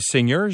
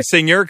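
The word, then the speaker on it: Singer: Et on va terminer avec le mont, on dit Seigneur, j'imagine? – C'est ce que je me suis demandé. Je le dirais comme ça, moi aussi. – Ça s'écrit Seigneur comme les machines à coudre.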